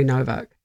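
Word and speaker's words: Nowak: I know it's spelt with a W, but that's how his name is pronounced, Henry Novak.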